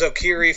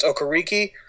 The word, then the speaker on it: Okereke: Very hard last name to pronounce. I thought it was Okiri for an ever, and then apparently there's an extra K on the back end of that name, so it's pronounced Okereke. Walker inevitably won that battle, like we talked about earlier.